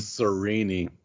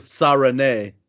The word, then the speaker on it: Sarene: See, this one is deceptive. It looks easy, but it is another one of those with the hard consonants. So it is actually Serene is the correct pronunciation. – Serene. – Yeah. – I was gonna maybe go with Sarene. Serene. Well, that sounds like an insult. Like that. Well, check her out. She's a real Serene.